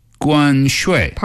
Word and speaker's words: 关税: And tariff in Chinese is 关税. – 关税. – Perfect. Again, this is 关, that's the first tone. 税 is the fourth tone. So you're going 5-5-5-3, right?